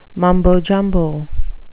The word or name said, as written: mumbo jumbo